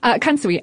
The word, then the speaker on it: kansui: And the noodles are made with a combination of flour, salt, and water that has often been infused with kansui.